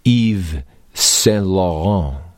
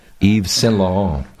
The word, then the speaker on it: Yves Saint Laurent: Now, of course, it's not so unusual, because we've all heard of the designer Eve Saint Laurent. Did I say his name right? – Oh, you said it beautifully. – Not bad, huh? Yves Saint Laurent.